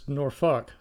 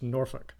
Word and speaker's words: Norfolk: Also, we pronounce it as Norfolk, so it's no L in there. It's just Norfolk.